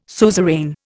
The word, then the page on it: suzerain: Indian